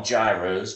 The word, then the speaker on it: gyro: Of course, we call them gyros, but I've always been told that they're called gyros. – Yeah. – Pronounce it the way they pronounce it in Europe. It's gyro, but we know it as a gyro, which is usually pork meat or lamb meat scraped off of a skewer into a flatbread with tajiki sauce and lettuce.